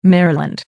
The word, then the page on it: maryland: American English